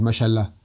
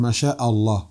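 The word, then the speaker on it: Masha'Allah (ما شاء الله): Lorsque les gens disent Masalan, ils disent Mashallah. C'est Masha'Allah. Ou Incha'Allah. C'est pas Incha'Allah, directement. Parce que le fait que tu enlèves une lettre, ça change le sens.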